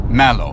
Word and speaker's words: Mallow: Or is that Mallow? – Mallow. – Mallow. It's a very macho pronunciation.